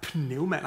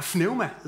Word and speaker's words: pneuma: Øhm, og på græsk, der er, øh, der er ordet for ånd, det er pneuma. Nej, pneuma hedder det. Pneuma. Hvordan udtaler man det? Pneuma. Sådan noget.